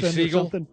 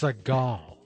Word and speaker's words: Sagal: First time I met her, I mispronounced her name. – Oh, what'd you call her? – Katie Siegel. Siegel. – Siegel. – And she goes, it's Sagal. Sorry.